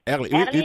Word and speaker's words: Erling: Erling. – Je prononce bien. – Erling, tu le prononces très bien. Tu le prononces très, très, très bien.